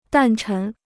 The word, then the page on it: 诞辰: dàn chén